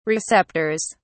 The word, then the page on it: receptors: British English